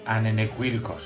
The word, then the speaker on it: Anenecuilco: Emiliano Zapata nació en 1879 en Anenecuilco. ¿Lo he dicho bien? Anenecuilco, sí, lo he dicho bien.